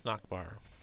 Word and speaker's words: Nachbar: James Nachbar. – And how do you spell that? – It's N as in Northern, A-C-H, B as in Bravo, A-R.